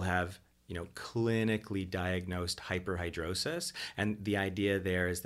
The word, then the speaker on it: hyperhidrosis: One of them, am I going to say it right? Hyperhidrosis. There we go.